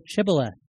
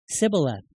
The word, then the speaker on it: Shibboleth: And when they said no, they said to him, say Shibboleth. And he said, Sibboleth. Different dialect, a little bit dialect, so they're able to tell who is who here, for he could not pronounce it right.